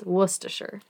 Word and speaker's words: Worcestershire: My party trick is that I know how to say Worcestershire sauce. Nobody knows how it's pronounced. It's Worcestershire. – Worcestershire. – Because it's Worcester, and Worcestershire is like the area.